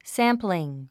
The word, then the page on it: sampling: sǽmpliŋ; サンプリン